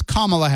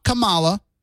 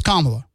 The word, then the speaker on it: Kamala: First of all, her name is Kamala Harris, not Kamala. And if you don't learn how to pronounce it correctly, then just call her Harris.